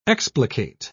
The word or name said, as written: explicate